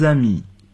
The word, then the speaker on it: amis: Jean et moi, nous sommes de vieux amis.